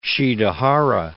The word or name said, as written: Shidehara